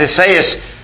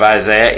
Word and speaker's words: Esaias: He answered and said unto them, Well hath Esaias, or Isaiah, if you will, that's the Greek pronunciation of Isaiah, Esaias,